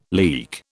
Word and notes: leak: British English

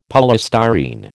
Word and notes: polystyrene: American English

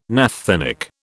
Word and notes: naphthenic: British English

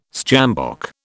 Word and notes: sjambok: British English

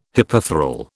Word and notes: hypethral: British English

